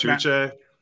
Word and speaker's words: Uche: I like Josh Uche. I don't know how to say his name. – No, that's right.